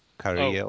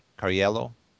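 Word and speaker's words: Cariello: No, it's a C-A-R-I-E-L-L-O. Carriello. Oh. Carriello.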